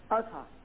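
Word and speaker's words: Ahaz: which has gone down in the sundial of how you pronounce it, Aza? – Yeah, Azha.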